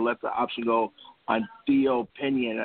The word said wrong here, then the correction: Pinson: I think I mispronounced his name, as well as Kenny Wooten. – Yeah, Theo Pinson.